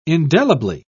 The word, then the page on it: indelibly: British English